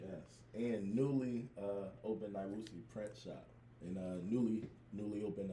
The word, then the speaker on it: Nyeusi: Nyeusi. – Nyeusi.